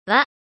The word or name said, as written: ワ